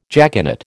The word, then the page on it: jaconet: American English